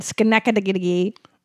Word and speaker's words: Schenectady: December 1st, I will be in Schenectady.